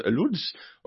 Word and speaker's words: Łódź: For Atleti versus, initially scored twice against Steaua Bucharest, and then he scored twice against Widzew Łódź, or Łódź, I think is the pronunciation of that.